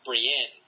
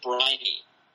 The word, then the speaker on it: Brienne: I pronounced the character Brienne. I pronounced her name wrong. – What did you... – in the chat. I said Briny. I got the name wrong.